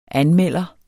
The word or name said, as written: anmelder